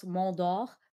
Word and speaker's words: Mondor: I feel like y'all also forgetting that Mondor, actually, his name is pronounced Mondor, but anyways. – Mondor. – Is it Mondor? – Is it? Is Mondor. That what it is? – Mondor. – Yeah, because it's French.